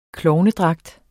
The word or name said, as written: klovnedragt